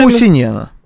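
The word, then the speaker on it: Куусинен: Кусинена подойдет? Или нет? Или не бедный, то. Да. Ты знаешь, кстати, что, по-моему, правильно говорить вообще Кусинен, но это, в принципе, невозможно выговорить.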